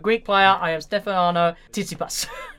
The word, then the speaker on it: Tsitsipas: It was Tsitsipas. It's actually how you pronounce it, yes. – That makes no sense. – Yeah, Tsitsipas is how you pronounce it.